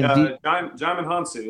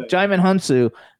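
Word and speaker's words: Djimon Hounsou: Jaimin Hunsu. Yeah. – Jaimin Hunsu.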